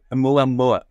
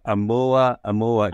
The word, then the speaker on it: Oumuamua: Oumuamua. Oumuamua. Just say it. – Oumuamua. – Oumuamua.